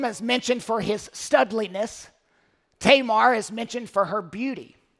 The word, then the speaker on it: Tamar: David's fourth wife bore him Absalom and Tamar. Or Tamar is probably the correct pronunciation. The A sound isn't really used in Hebrew. You're used to hearing it pronounced Tamar, so I will stick with that throughout.